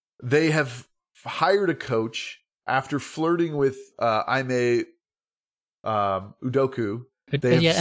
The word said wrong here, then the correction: Udoka: since you know what, we've been pronouncing it wrong. It's Udoka.